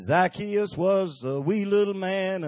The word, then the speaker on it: Zacchaeus: I finally found out how to pronounce the man's name, Zacchaeus.